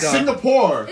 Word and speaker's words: Singapore: It's Singapore. – It's not Cigna.